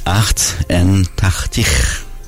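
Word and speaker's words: achtentachtig: بهش میگن اخت ان تختیخ،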